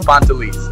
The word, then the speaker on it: Pantelis: So Pantelis is the first name. The last name you did really good, but the first name is Pantelis.